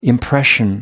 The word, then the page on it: impression: American English